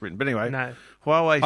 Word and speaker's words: Huawei: And Huawei, is that how you pronounce them? The Chinese menu? – Huawei. – What he said. Yeah, Huawei. That is how you say it.